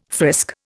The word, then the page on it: frisk: Indian